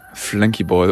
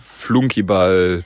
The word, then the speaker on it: Flunkyball: Ich hoffe, man merkt mir nicht so ganz so an, dass ich schon zwei Runden Flankyball oder je nachdem, je nach Region in Deutschland, Flunkyball, Flankyball, whatever, wird überall anders ausgesprochen.